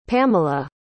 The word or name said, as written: pamala